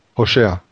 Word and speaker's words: Hosea: Is that how you say it in the Hebrew? – Hosea. – Hosea.